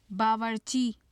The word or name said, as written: bawarci